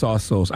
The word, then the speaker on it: Tsotsos: Okay, his name is pronounced T-S-O-T-S-O-S. And according to the how to pronounce thing, when you Google it, it's pronounced Sossos.